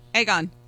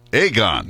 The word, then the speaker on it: Aegon: But it's called, you got to pronounce it. – I got it. – Aegon. Aegon. – Yes. There you go.